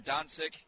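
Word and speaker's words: Dončić: And I'm not sure I'm pronouncing that name right. Doncic or Doncic? – Doncic. – Doncic, okay. – Yes.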